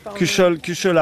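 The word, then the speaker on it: cuchaule: Un cuchol pour préparer le. Cuchol AOP, fait dans la région, bien évidemment. Cuchol, voilà, pour bien prononcer. – Ouais, c'est ça. Ouais. – Cuchol avec l'accent broyard.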